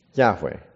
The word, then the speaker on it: Yahweh: So we already have studied the word Jehovah, that it is a way to pronounce that four-letter personal name of the Lord, which is probably better pronounced as what? Yahweh, right?